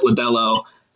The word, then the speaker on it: Lodolo: I heard John Mayos, the guy on the TV, he pronounced it Lodolo. And so I believe that's gospel now.